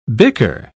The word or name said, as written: bicker